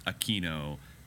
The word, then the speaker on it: Aquino: But I think because it's rooted in Spanish that it is pronounced Aquino if you're talking about, say, anybody in the Philippines or in Spain or Latin America.